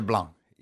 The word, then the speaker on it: Blanc: We're talking about their second album, Regatta de Blanc. – Yeah. – Do you really pronounce the C? – You hang off that C. I like that. That's very French.